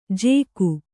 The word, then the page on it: ಜೇಕು: jēku